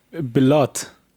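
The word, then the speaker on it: Bilott: Wie hatten wir uns jetzt geeinigt, wird er ausgesprochen? – Robert Bellotte. – Bellotte.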